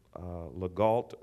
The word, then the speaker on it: Legault: L.A., or his, you might call him, or might say, Alan LeGault, or it's pronounced Legault. and they pronounce his name in the French, and I'm sure I'm not doing it justice, but it's Elie Legault.